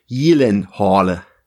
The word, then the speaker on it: Gyllenhaal: also bei uns ist er natürlich äh, weitestgehend bekannt als Jake Gyllenhaal, aber tatsächlich ist die richtige Aussprache, das wusste er aber wahrscheinlich selber nicht, ist tatsächlich Jelenhorle. Schwedisch.